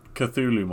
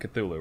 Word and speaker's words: Cthulhu: Like, so we're both pronouncing it Cthulhu, right? Apparently, the way Lovecraft originally pronounced it was, Cthulhu. – Oh, really? – Yeah. It was very different from how most people pronounce it today.